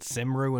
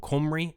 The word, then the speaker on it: Cymru: And I believe it is now Cymru and Western.